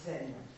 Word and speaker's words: Zen: Et Chan, c'est donc la prononciation chinoise, c'est un terme qui se prononce en japonais Zen.